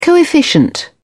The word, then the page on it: coefficient: British English